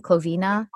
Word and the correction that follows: Cliovana: oh, Cliovana. Cliovana uh, when we're out of quarantine.